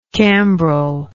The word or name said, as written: cambrel